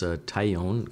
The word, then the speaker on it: Taillon: It's a French name, T-A-I-L-L-O-N. – That's right, yeah. So it's pronounced Taillon, kind of an English Taillon in French.